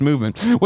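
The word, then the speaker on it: MVMT: Movement Watches, pronounced Movement, was founded on the belief that style shouldn't break the bank.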